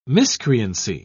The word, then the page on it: miscreancy: British English